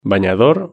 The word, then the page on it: banador: Spain Spanish